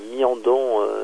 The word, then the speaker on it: Miandon: Alors ça s'écrit Mon comme un mont, Miandon, M-I-A-N-D-O-N.